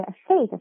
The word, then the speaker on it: thick: 那大家注意一下这个 thick 这个发音。 fake fake th 要咬舌头哦。